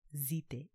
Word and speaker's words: zite: That's how you Americans say it, but in Creole it's zite. I don't even know if I'm saying it right. Like, does it start with a Z? Does it start with a D? Zite.